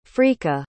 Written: frika

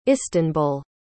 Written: istanbul

